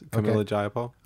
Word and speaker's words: Pramila Jayapal: Your representative, Pramila Jayapal. – Uh-huh. – Did I say it right? – Yes, Pramila Jayapal.